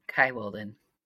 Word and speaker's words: Kia Wolden: Yep, Kia Wolden.